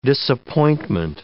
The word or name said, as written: disappointment